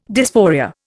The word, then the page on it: dysphoria: Indian